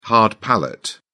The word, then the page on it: hard palate: British English